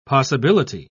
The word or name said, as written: possibility